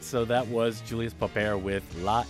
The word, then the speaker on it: Popper: Yeah, I think they would pronounce it Popper. Popper, yeah.